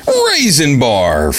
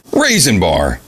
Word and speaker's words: Rayxanber: But I also mentioned another game, Raisin Bar, for the PC Engine. It's spelled R-A-Y-X-A-N-B-E-R. Raisin Bar.